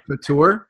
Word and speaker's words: couture: Did I pronounce that right? That's a very nice word. Couture. – Couture, yeah.